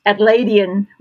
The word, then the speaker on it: Adelaidean: Adelaidean wisdom? Does that sound right? – Almost right. We tend to slur all our syllables together, so it's an Adelaide wisdom, Adelaidean wisdom. Yep.